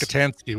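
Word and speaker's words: Rockatansky: It's Rokotinsky or Rock. It's pronounced different, but now you've thrown me. But it's that one. – Mm-hmm. Rokotansky. – Yes. – Rokotansky with an A.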